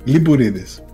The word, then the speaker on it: Lympouridis: Lympouridis.